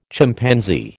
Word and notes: Chimpanzee: American English

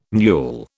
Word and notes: mewl: British English

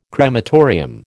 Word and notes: crematorium: American English

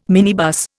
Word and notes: minibus: Indian

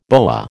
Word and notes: boa: American English

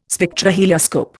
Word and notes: Spectrohelioscope: Indian

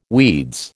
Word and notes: weeds: American English

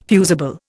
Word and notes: fusible: Indian

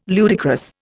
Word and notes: ludicrous: Indian

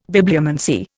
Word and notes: Bibliomancy: Indian

